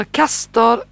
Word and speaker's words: Castor: Jag påstår att de båda stjärnorna i tvillingarnas stjärnbild heter Castor och Pollux, tror jag man uttalar det. – Rätt.